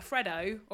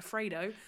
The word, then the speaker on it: Freddo: And obviously a Freddo, or as Benjamin likes to call it, a Fredo. – No! – Fuck off. – Sorry, babe. Sorry, babe, I'm out on you again for the way you pronounce shit.